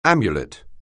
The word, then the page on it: amulet: anglais